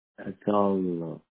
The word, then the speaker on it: Adarlo: Last name is A-D-A-R-L-O. – Adarlo.